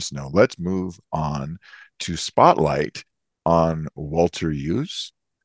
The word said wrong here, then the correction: Iooss: Yes. – Yost, excuse me, Yost.